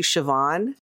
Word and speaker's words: Siobhan: I'm going to change it to Siobhan. Good old Irish name. S-I-O-B-H-A-N, because, you know, that's how it's pronounced. Siobhan. – For now. – Gaelic is not English.